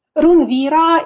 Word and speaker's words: RUNVira: You have Ancestral Fire, and then is it Runvira? Is that how you say it? – Yes, it's actually Runvira.